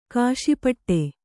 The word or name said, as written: ಖುಷಿಪಡು